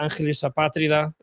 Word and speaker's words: Angelus Apatrida: And there are some Spanish bands, which I feel, for example, there's a band like Angelus Apatrida, Angelus Apatrida, it would be pronounced in Spanish, mm-hmm.